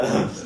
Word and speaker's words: Nantes: Angers, aptly named, Nantes or Nice? – You did well with the Nantes. – Yeah. – Nantes.